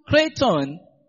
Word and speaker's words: kreitton: The word best is the Greek word kraton. The word best is the Greek word kraton, which is spelled K-R-E-I-T-T-O-N.